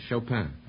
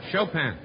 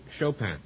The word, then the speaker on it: Chopin: How do you like my French? Chopin. – I think that's very good. Say it again. – Chopin. – How would you say it if it were Polish? – Chopin.